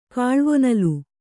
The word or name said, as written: ಕಾಲ್ವೊನಲ್